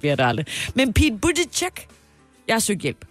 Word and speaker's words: Buttigieg: it all boils down to the vowels. Buttigieg. The vowel in the first syllable is not like boo, but like book. Uh, book. And the last syllable isn't the a vowel like in judge, but it's the unstressed i vowel like in steerage. Idge, idge. So not uh, but uh. Buttigieg, judge, judge, judge, Buttigieg.